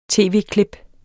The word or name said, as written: tv-klip